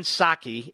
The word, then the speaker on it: Psaki: So Peter Ducey to Jen Psaki, it's spelled Psaki, but the P is silent.